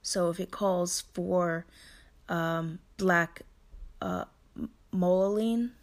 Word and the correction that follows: tourmaline: I believe that's how you say it. Let me. Um, look it up so I can spell it. Uh, back black tourmaline, T O U R M A L I N E.